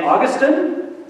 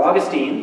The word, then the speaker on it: Augustine: St. Augustine, or Augustine, depending on how you want to pronounce it.